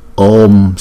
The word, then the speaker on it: Om: Bởi vì chữ O là diphthong, chúng ta phải đọc chữ O kéo dài hai nhịp trước, rồi chúng ta mới đóng chữ O lại sau là Om, Om, Om.